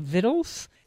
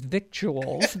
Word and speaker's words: victuals: And in our next segment, we have Ronnie Lundy, who has done a book from the Appalachian Mountains, and it's called Vittles. We look at it and think it says victuals, but it's vittles. That's how it's said.